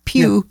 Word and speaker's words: più: It's spelled P-I-U. Pew yeah.